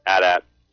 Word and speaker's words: AT-AT: AT-AT.